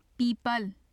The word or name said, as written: pipal